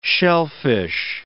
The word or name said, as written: shellfish